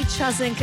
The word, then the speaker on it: Tjasink: Her name is Amy Chazink, and for those who are asking how to spell it, the surname is T-J-A-S-I-N-K. Amy Chazink is the artist.